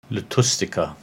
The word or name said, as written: Lotustica